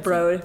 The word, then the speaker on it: Broad: The Broad.